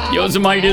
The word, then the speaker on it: Yosemite: Yosemite is right, though.